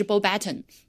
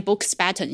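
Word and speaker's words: Beauxbatons: 用用英文念也是念的是 b o button，他不会念 books button，因为这个科斯是不会发音的，好吗？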